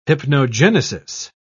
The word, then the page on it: hypnogenesis: British English